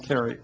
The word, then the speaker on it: Carey: Carey? Is that correct? – Yes. – All right, can you spell that for us? – C-A-R-E-Y. – All right. Thank you, Ms. Carey.